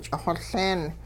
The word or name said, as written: ch'ixoLeen